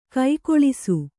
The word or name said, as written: ಕುಕ್ಕುಳಿಸು